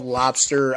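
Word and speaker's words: Lobstr: And yes, it is called Lobster. I know it is spelled different. It's L-O-B-S-T-R. So leave out the E in Lobster.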